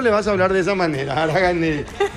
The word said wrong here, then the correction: Araguanei: No, a ver, ¿qué dice? Araguanei. – Araguanei. – Araguanei, Araguanei. No, Aragani. – Araguanei S.A. Ya lo dije bien.